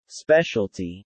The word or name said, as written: specialty